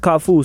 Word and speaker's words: KAFV: It's spelled, obviously, K-A-F-V. – Yeah, I mean, the V is just to flex, just to like, you know, give it that Google twist, you know. So if you Google, like, it's pronounced Kafu. So if you Google like K-A-F-U, you get all sorts of like companies and brands and wrestlers and random stuff.